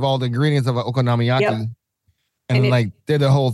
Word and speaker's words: okonomiyaki: Okonomiyaki. – He did that, but it was an okonomiyaki. – Okonomiyaki.